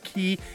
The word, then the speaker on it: Cay: Now, I know it's spelled C A Y, but it is pronounced Key.